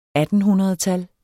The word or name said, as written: attenhundredtal